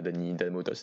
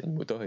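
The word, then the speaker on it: Dany Mota: Euh, mais déjà à l'époque, enfin j'avais mal prononcé son nom. Euh, c'est pas Dani Mota c'est Dani Mota et ça,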